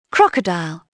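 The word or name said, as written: crocodile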